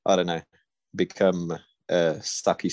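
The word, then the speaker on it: sakkie: It would have sucked to be a sucky, sucky musician.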